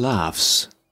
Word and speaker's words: laugh: The lion laughs. The lion laughs. – Laughs, all right? L-A-U-G-H. Yet the pronunciation is laugh.